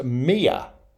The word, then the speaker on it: mio: And in Neapolitan, the word spelt M-I-O is pronounced Mia, as if it's got a Y-U-H at the end, Mia.